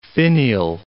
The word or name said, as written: finial